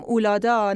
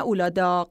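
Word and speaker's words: Uludağ: میگیم اولادا نه اولاداق، چون میگن که قه آخر تلفظ نمیشه و امیدواریم که درست باشه.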